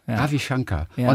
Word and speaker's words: Shankar: Aber nee, also es kommt ja eigentlich aus dem Indischen es wird eigentlich Shankar ausgesprochen.